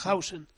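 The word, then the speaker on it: Huijsen: Heisen. – No, sí, se pronuncia Hausen. – ¿Se llama Hausen o Heisen? – ¿Hausen? – Se pronuncia Hausen, sí, sí.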